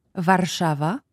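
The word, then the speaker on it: Warszawa: puis Varsovie, ça se prononce comme ça. varshava Fait que, bon, le, un bateau qui est sur euh, la Vistule.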